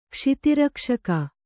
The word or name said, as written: ಕ್ಷಿತಿರಕ್ಷಕ